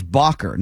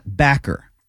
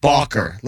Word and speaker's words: Bacher: And he was introduced as Bacher, not Backer. But Bacher. – Like John Sebastian Bach.